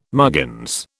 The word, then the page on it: muggins: British English